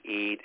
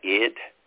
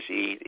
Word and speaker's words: Eid: So it is Eid. Imam Muhammad for years tried to, he would spell it different ways. He would put three E's there to make sure we wanted to say Eid, you know, with the long E sound in terms of transliteration so that we would pronounce it correctly.